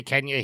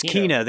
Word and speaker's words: Kena: I say Kenya, he says... – It's Kena. They said it in the game. They said Kena.